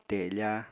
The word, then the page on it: tefa: apatani